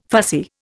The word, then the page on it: fussy: Indian